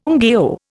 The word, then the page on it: ngaio: Indian